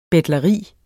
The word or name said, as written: betleri